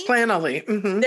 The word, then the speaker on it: Planoly: I know I use, like, they have I don't know if I'm saying it right, but Planoly. – Planoly. – There you go.